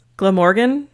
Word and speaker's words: Glamorgan: Glamorgan. – It's perfect. – But there's other words that are like shenangela. I don't know. S-E-N-G-H-E-N-Y-D-D. Nope. – Some Welsh for you right there. – Near Caerphilly, Glamorgan, Wales.